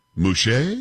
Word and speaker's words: Mushait: M-U-S-H-A-I-T, Mushait? Can we say it? We're going to go with that? Polo, are we good with that? You're not done with that, are we? – Say it the way you need to say it. – Mushe? – Yes. – Yes, good.